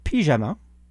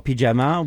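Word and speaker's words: Pijama: But it's not spelled like a pyjama in French. It's P-I-J-A-M-A. And it's the letters are separated. – Interesting. – Yeah. P like pie and ja like ja in German and ma like...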